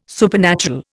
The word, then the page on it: supernatural: Indian